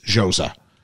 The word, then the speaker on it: Xhosa: Xhosa. X-H-O-S-A. I'm believing that would be pronounced Xhosa.